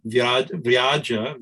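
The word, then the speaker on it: vyāja: And it's this nirvyaja, nir, without, and vyaja, V-Y, long A, J, long A, my tongue's not doing it today, right?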